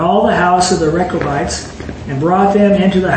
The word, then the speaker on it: Rechabites: Rechabites. – Rechabites, and speak with them, and bring them into the house of Yahweh, into one of the chambers, and give them what? – Wine.